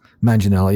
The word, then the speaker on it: Manganiello: You know, if you know who he is, but Manganiello.